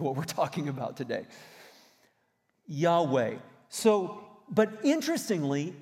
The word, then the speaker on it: Yahweh: These letters are Yod, He, Vod, He. Yod, He, Vod, He, which pronounced sounds something like Yahweh.